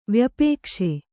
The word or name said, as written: ವ್ಯಪೇಕ್ಷೆ